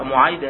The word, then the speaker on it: معيد: يكتب في آخرها، لكن ابن مكولة مثلا يذكر في الاشتباه مع معبد، يذكر في الاشتباه مع معبد، يقول هناك معبد ومعيد، انتبه، معيد هذه بالياء، معبد بالباء، ومعبد بفتح الميم، لكن معيد بالضم، صحيح؟ إذا هي تختلف في الحرف الثالث هذا بالياء والآخر بالباء، وأيضا في النطق هذا بضم أوله ضم الميم والآخر بفتح.